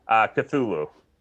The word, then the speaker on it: Cthulhu: Cthulhu.